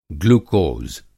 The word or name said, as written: ग्लुकोज़